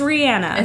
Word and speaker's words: Rihanna: Rihanna is Rihanna.